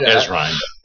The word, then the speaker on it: Ezrine: Ezrine. – Ezrine? Uh-huh. Okay. – Just like it's spelled.